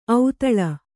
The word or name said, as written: ಔತಳ